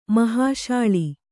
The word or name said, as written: ಮೋಹಸಲೆ